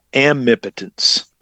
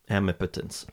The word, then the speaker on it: Amipotence: The book is called The Death of Omnipotence and the Birth of Amnipotence. – Amnipotence. All right. I was close.